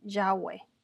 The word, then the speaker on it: Yahweh: En español se escribe y se pronuncia Yave Y-A-V-E. En inglés se pronuncia también Yahweh con W y a veces lo deletrean YHWH.